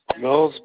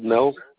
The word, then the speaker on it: Mills: Mills, Bob Mills. – You say your zip code was 42376? – Yep. – Okay, and what was your name? – Bob Mills. – Spell that for me. – B-O-B-M-I-L-L-S.